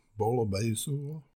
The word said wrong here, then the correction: bouillabaisse: Bouillabaisse. – Anyway. – Like booyah without the ah. Bouillabaisse.